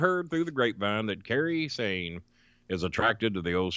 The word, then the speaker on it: Kairi Sane: I think I'm pronouncing it right. Kairi Sane? – Kairi Sane, yes.